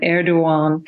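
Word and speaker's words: Erdoğan: but Erdogan.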